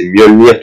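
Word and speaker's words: Mjölnir: Donc en fait, le J dans, dans les langues nordiques, c'est comme un I, donc c'est Mjolnir tout simplement. imagine qu'il y a un I à la place du J. En fait, Mjolnir,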